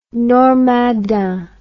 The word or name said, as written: Normandin